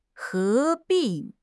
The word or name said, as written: 合并